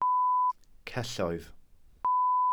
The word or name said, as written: celloedd